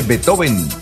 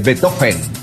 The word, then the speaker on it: Beethoven: Una vez pronuncié Beethoven y mi hijo no se dice Beethoven, te dicen es eh, Beethoven.